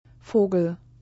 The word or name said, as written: Vogel